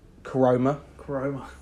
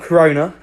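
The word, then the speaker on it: Koroma: Coroma. Not Corona, Coroma.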